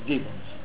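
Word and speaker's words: Jevons: Digamos, los autores fundamentales se llamaron, el inglés se llamaba Gibbons, con J y B corta, Gibbons, ¿no? Se pronuncia Gibbons.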